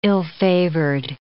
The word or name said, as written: ill-favored